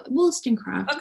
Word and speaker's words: Wollstonecraft: Wollstonecraft. Is that how you say it? Yeah, Wollstonecraft.